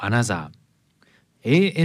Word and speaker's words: ANOTR: オープニングナンバーはアナザーで Talk to You フィーチャリング 54ウルトラでした。軽快で乗れる一曲ですね。さて、オランダはアムステルダム出身の二人組デュオアナザー、 ANOTR と書いてアナザーと読みます。